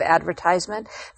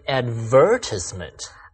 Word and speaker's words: advertisement: Why do I hear some people say advertisement? – Ah, that's the British pronunciation. Americans say advertisement, or ad for short.